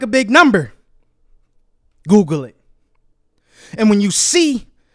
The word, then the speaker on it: googol: No, I'm talking about the actual mathematical term Google. It's spelled G-O-O-G-O-L.